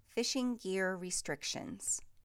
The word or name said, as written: Fishing gear restrictions